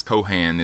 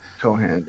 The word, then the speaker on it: Cohan: First of all, I'd never heard of George M. Cohen. I don't know why, or actually it's Cohan is how it's pronounced, but Cohan, yeah.